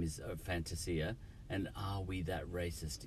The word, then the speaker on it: Fantasia: We're pronouncing it wrong. We're anglicising it. He's Italian. It should be Fantasia.